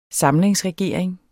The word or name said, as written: SAMLINGSREGERING